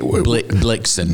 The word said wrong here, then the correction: Blitzen: Blitzen? – You said Blixen.